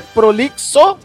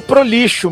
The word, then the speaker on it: prolixo: Agora, eu não sei se o Jota quis dizer nesse comentário se o Chico é prolixo ou prolixo mesmo. – As duas coisas. – A gente, né? Os dois, as duas pronúncias são válidas, né? – Válido.